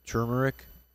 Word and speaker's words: turmeric: You know, turmeric is pronounced turmeric. Did you know that? – With a C-H, turmeric? – No, turmeric, because it's T-U-R. No one sees that sneaky little R.